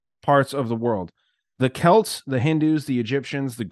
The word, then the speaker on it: Celts: I gotta interrupt and say thank you for pronouncing Celts correctly. – Yeah, what do people say? Celts? – Celts. Because of the Celtics, but thank you.